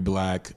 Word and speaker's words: 6LACK: That's 6LACK, and no matter what you think, it's still pronounced Black.